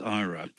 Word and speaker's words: IHRA: IHRA is pronounced IRA, but they haven't given it any effect.